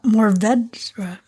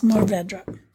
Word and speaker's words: Mourvèdre: So Mauvedre. – Yeah. – Mourvèdre. – I was listening to an actual Frenchman pronounce this and they kind of roll that last bit.